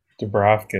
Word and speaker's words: Dubravka: I don't want to butcher the man's name, Dubravka. – Dubravka, yeah.